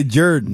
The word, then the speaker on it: Jordan: But they say Jordan. That's how you say it. See, I didn't know that. I would have said Jordan, like Michael Jordan.